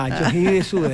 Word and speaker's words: Yoshihide Suga: Yoshihide Suga.